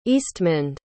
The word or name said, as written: eastmund